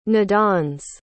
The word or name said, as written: nodons